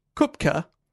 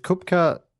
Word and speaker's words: Koepcke: Is Kupka pronounced cupcake in Australia? – No, it's actually spelled K-O-E-P-C-K-E. And if I'm pronouncing that wrong, blame the guy on YouTube I watch pronounce it.